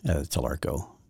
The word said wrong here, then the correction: Talarico: Talarico. It's Spanish, I think, something, or maybe... Maybe Italian, but I think Dan Patrick went across the line there a little bit.